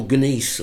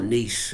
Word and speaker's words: gneiss: They call gneiss or gneiss, it depends how you want to pronounce it.